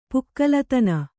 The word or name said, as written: ಪುಕ್ಕಲುತನ